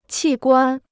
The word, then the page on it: 器官: qì guān